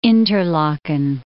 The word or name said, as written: Interlaken